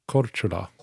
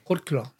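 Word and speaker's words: Korčula: Kortula. – Korkula.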